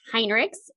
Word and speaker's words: Hinrichs: My last name is pronounced Hinrichs. It's very German.